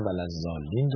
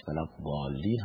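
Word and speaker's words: الضالين: نه ولد زالین درسته نه ولد دالین با دال درسته تلفظ صحیحش ولد بالین هستش که با کنار گرفتن یا چسباندن بغل زبان با دندان آسیاب چپ یا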